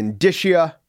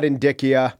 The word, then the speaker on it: indicia: It's indicia. It's not indicia.